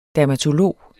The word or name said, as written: dermatolog